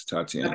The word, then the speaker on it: Tatiana: Your daughter, am I pronouncing her name correctly? Was it Tatiana? – Yes, Tatiana.